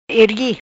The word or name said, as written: Ergui